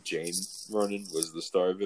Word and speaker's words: Saoirse: I'm telling you, like, I've heard her pronounce her name, and she pronounces it Saoirse. Like, it's Jane. – No, it's Jane.